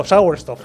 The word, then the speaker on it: Sauerstoff: Sauerstoff, ¿no? No sé si lo he dicho bien. – Perfecto, Sauerstoff.